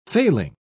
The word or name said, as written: failing